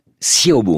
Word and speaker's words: Xiaobo: Xiaobo, on prononce ça. Hein. Le X, en l'occurrence, comme deux S.